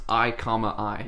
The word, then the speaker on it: i,i: But it is pronounced I, I.